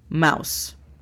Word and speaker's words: mouse: D-E-S-K-T-A-P, desktop. Laptop. – Computadora portátil. – La pronunciación fonética es L-A-P-T-A-P, laptop. La siguiente palabra es mouse. – Ratón de escritorio. – La pronunciación fonética es M-A-U-S.